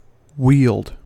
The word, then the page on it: wield: American English